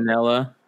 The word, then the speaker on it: Manganiello: How do you say his last name? I can't say it. – Manganiello.